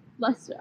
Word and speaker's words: Leicester: It's another commonly mispronounced place is Leicester Square. – Leicester. – It looks like Leicester. It looks like everything but Leicester Square.